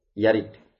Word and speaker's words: yarid: Yarid. Ya benar bacanya yarid.